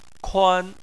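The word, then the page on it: kuan: tone 1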